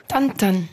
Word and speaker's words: Tintin: J'en reviens juste à mon ten-ten. On prononcerait comme ça? Vous diriez comment, vous? – Tan-tan.